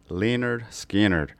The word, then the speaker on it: Lynyrd Skynyrd: That was actually after first album released in 1973, pronounced Lynyrd Skynyrd.